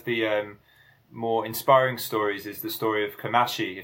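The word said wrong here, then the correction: Kamaishi: Yeah, I believe it's pronounced Kemaishi, but Kemaishi. I'm no expert in Japanese.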